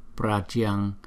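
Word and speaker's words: Pratyaṅgire: Đó, chỗ này là Pratyangire, nhưng mà chúng ta đọc nhanh thành là Pratyang, Pratyangire, Pratyangire.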